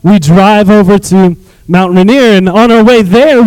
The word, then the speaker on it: Rainier: I said, "Well, first of all, that's how you pronounce Rainier, spelled R-A-I-N like rain, I-E-R, Rainier."